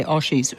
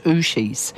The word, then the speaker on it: ushies: I mispronounced it. I keep wanting to say oshies, ushies.